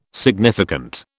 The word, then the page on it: significant: British English